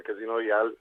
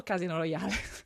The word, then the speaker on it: Casino Royale: Allora, dipende, nel senso Casino Royale è la pronuncia normale, poi io personalmente dopo dicevo Casino Royale perché... Perché, perché l'atmosfera era quella che era? – Ma perché è scritto con la E alla fine.